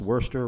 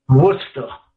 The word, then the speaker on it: Worcester: Did I pronounce Worcester right? – Well, no, you didn't. – Oh, how do you pronounce it? – Worcester. – Worcester. Corey in Worcester.